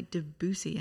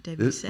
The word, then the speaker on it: Debussy: It's pronounced apparently Debussy and not Debussy. – Really? – We were corrected by an Englishman.